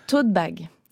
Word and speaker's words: tote bag: Et ça m'a permis de me rendre compte que j'avais un vrai problème de tote bag. Les tote bags, qu'est-ce que c'est? Alors ça se prononce comme la contraction de taupe et haute,